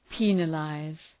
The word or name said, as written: penalize